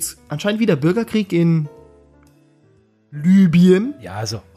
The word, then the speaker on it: Libyen: Also Leute, bitte spricht es richtig aus. Libyen.